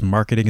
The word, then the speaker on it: marketing: And so here, where is the stress? In the first, second, or third syllable, where do you hear it? Marketing. Okay, so it's going to be in the first syllable.